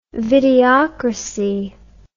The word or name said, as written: videocracy